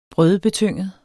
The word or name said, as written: brødebetynget